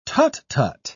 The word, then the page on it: tut-tut: British English